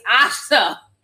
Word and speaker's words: Asha: It's Asha. As in amazing to see ya, you know? Thank you so much. I know I always say Asia because it's spelled Asia. It's Asha.